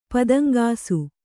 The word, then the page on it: ಪುದುಂಗಿಸು: puduŋgisu